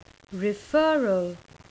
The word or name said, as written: referral